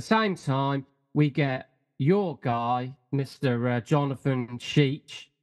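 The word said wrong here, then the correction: Schaech: I think it's Sheck. I actually looked at the pronunciation. The pronunciation is S-H-E-K when they put it on, like, when you pronounce it. So it's like Shrek, yeah? – Like Shrek, but without the R.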